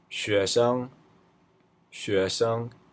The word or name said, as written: xuesheng